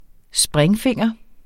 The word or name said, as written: springfinger